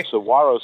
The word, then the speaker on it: Saguaro: It's like Guacamole is actually pronounced, the G is pronounced as a W. – So, oh, okay. – Saguaro Sisters. It's spelled with a G but pronounced with a W.